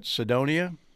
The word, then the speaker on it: Sidonia: Knights of, is that Sidonia? Is that how you pronounce that? – Yeah.